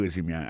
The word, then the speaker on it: résumé: Moi, ouais, nan CV ou nan curriculum vitae ou, gade ki jen yo prononce mon résumé, assis fom yon résumé, accent tombé sur premier syllabe là.